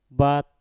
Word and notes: bat: mpur

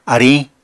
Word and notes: ariñ: Apatani

